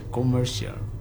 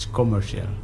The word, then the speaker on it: commercial: It's commercial. – Commercial, okay. – Because in English pronunciation, sometimes we cannot hear the very hard for the R, yeah. – Okay. – Commercial. – R-nya agak sedikit tersembunyi.